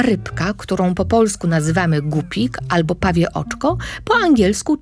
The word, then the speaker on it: gupik: Nazwę gupik pisze się przez U, w języku angielskim mówimy guppy, ale po polsku to ciągle jest gupik. Tylko pamiętajcie, tam nie ma głoski ły w środku. To nie jest rybka głupia. O, nie, nie. Rybka jest bardzo sprytna, bardzo mądra. Dlatego gdy wymawiacie jej nazwę, pamiętajcie, żeby tam żadnego ły nie wciskać.